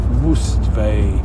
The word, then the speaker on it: Mustvee: is going to Mustvee. It's spelt Mustvee as must see, but with a V instead.